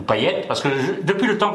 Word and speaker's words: Payet: Non, c'est à La Réunion d'où je viens, ça se dit Payette. Donc effectivement, ceux qui disent Payette, ils ont raison.